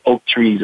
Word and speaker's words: Oak3s: How do I pronounce your company? – Yeah, it's a, it's a, it's a tricky uh, word that I built myself. It's Oak Trees, actually. – Oak trees.